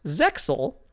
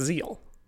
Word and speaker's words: Zexal: Is spelled Yu-Gi-Oh Z-E huge X-A-L. It's very frequently pronounced Zexal. The X is not actually a letter in that word. It is just a design. And so according to the Japanese, it's pronounced Zeal. If you like look at the Japanese yeah. like writing. – Z-E-A-L, yeah.